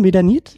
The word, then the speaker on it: Medanit: Jetzt muss ich nachgucken, Medanit. Hallo. Hi. Äh, ist das richtig ausgesprochen, Medanit? Ja, perfekt.